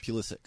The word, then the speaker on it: Pulisic: forgive me on the pronunciation, Pulisic? – Pulisic. – Yeah.